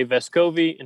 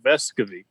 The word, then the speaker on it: Vescovi: is more like Vescovi the emphasis on the first part of the word.